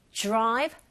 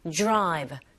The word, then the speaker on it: drive: Great drive, nothing stop. 내용어고요. 자, 이때 드라이브로 발음하지 마시고 drive, drive. This sound, R sound 같이 붙어있을 때 약간 J에 가깝게 소리가 들리거든요. Drive.